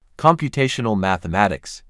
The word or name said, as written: Computational mathematics